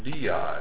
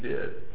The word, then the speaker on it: Dei: Vicarious, fili, and the word third word was the word di. That word is spelled D-E-I.